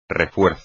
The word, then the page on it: refuerzo: espagnol